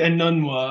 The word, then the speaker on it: Enunwa: Yeah, you know, people got a little overzealous, I think, with how do you pronounce this, by the way? – I say Enunwa. – I don't know. – I'm right, you're wrong.